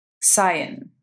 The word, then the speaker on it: Samhain: I've looked this up many a times to make sure it's pronounced to sound like S-A-A-W-N, but to spell it is it's S-A-M-H-A-I-N.